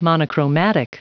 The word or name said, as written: monochromatic